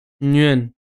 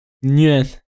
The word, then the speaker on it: Nguyen: Den Nachnamen spricht man wie äh, spricht man eigentlich wie Nürnberg aus, nur ohne Berg. Hm, halt ein bisschen, bisschen smoother. Nürn. Genau. Nguyen. Dr. Mai T.